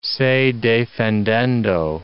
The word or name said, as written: se defendendo